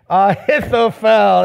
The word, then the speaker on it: Ahithophel: Say it with me. Ahithophel. There you go. Just say it like it's spelled.